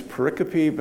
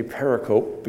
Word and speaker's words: pericope: And it struck me in my study, trying to figure out, you know, what's the correct pronunciation is pericope, but I like to say pericope because it makes Mark's teeth go on edge.